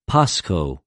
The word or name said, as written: πασχω